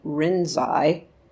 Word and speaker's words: Rinzai: And Huangbo's main student, he transmitted to a bunch of people, but his main student was Lingzhi, or in Japanese we pronounce the name Rinzai, who he founded the Rinzai School of Zen.